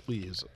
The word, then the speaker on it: Lies: Spelled L-I-E-S. – Yes, Lees. I would pronounce it that way, too.